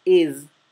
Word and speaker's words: is: Luego, la palabra is, que significa es del verbo to be, se pronuncia con la S zumbada. Z, no es una S suave, no es is, es is.